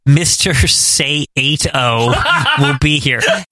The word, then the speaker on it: Saito: Like S-A-Y, like say. – Yeah. – The number 8. Oh, yeah. Saito.